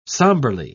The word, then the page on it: sombrely: British English